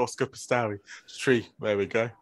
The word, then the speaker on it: Piastri: Oscar Piastri. Piastri. – Yeah, not... – Oscar Piastri.